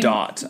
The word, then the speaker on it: Gadot: Gadot, I believe, is the correct pronunciation.